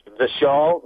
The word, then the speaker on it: Vishal: Yes, Vishal, V-I-S-H-A-L, Mangalwadi.